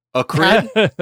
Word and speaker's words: acrid: Is the word pronounced acrid? Acrid? – It's acrid.